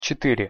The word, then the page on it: cetyre: Russian